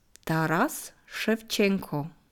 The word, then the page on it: Taras Schewtschenko: slow